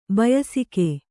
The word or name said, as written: ಬಯಸಿಕೆ